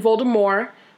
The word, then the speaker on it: Voldemort: But so basically we've been pronouncing Voldemort's name wrong, apparently. – What? – So the T is silent. It's actually Voldemort,